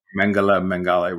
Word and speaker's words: Mengele: I'm wondering, and I thought I mispronounced his name, but Joseph Mengele. – Mengele, right.